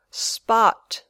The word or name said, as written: spot